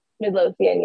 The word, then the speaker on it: Midlothian: is it Midlothian? – Midlothian, yeah.